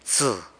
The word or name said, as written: btsë